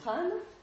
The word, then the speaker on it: Han: Donc les principaux noms que je vous citerai, c'est la dynastie des Han, H se prononce avec un R, dynastie des Han.